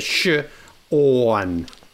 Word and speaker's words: Sean: it was S-E-A-N, and I was like, how do you get Sean out of that? I was like, scene, that's a scene, you know. But Sean Kemp is actually phonetically, I think, the best way to spell it, because it's the sh-orn, like that.